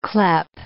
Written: clepe

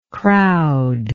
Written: crowder

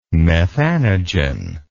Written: methanogen

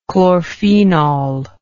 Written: chlorphenol

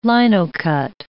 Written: linocut

